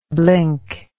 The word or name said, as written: blinkard